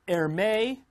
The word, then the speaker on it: Hermès: Dressed in Hermès was your favorite, was amazing.